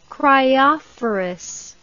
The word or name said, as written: cryophorus